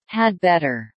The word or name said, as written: had better